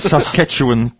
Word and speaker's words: Saskatchewan: I'm a network administrator / computer technician for a rural school district in Saskatchewan, Hatchikachikan, Canada. – That's not how you pronounce it, is it? – Saskatchewan, Canada.